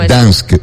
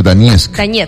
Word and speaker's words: Donetsk: No se dice Dansk, sino Donetsk.